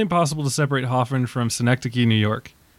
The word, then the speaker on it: Synecdoche: And we'd also just talked about am I saying that right? Synecdoche. Okay. – Synecdoche. – Yeah, Synecdoche. Kind of sounds like Schenectady.